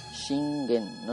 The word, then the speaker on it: Shingen: Takeda Shingen, como digo, se pronuncia así, Shingen, no se pronuncia Shingen o Shingen, ¿vale? En japonés, la sílaba G, bueno, se escribe G en español, G-E, ¿vale? Siempre se pronuncia como nuestra G suave.